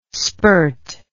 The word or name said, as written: spirt